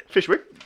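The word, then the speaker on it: Fyshwick: Fishwick. That's how the locals pronounce it.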